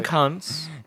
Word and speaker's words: Koontz: Did you know it's actually pronounced cunts? I'm not making that up. – Really? Google, Dean Koontz? – No, it's Dean Koontz.